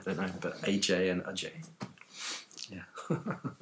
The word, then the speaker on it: Ajay: Or is it AJ? I never quite. Um, the English, yeah, the English pronunciation, I suppose, is AJ, kind of growing up in, in, in London, but uh, Indian pronunciation is Ajay.